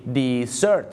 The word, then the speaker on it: dessert: อย่าลืมนะครับคำว่าของหวานสะกด d e SERT นะครับหวานดีอร่อยดี dessert นะครับ dessert ก็คือของหวานครับแต่ถ้าเป็นทะเลทราย